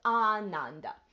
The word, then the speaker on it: Ananda: remember that the first A is long and it's Ananda. And you don't even have to pronounce it. When you think it in your head, think the long A.